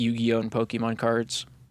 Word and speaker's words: Yu-Gi-Oh!: Yu-Gi-Oh! Is Japanese, and we say it the same as they do.